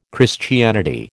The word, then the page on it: Christianity: American English